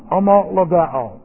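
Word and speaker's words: homologao (ὁμολογέω): H, the rough breathing here, we give this an H-O sound, ho, and then M-O, and then L-O-G-E, long O, homologao, homologao.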